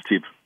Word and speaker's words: STIB-MIVB: We actually, in Brussels, we call it STIB, which is the first acronym that that we use.